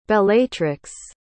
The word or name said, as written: bellatrix